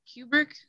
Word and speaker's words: Kubrick: But at the same time, with most of that director's films, it's Stanley Kubrick.